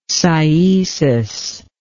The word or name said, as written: cyesis